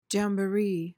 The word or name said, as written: jamboree